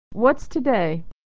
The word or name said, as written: what's today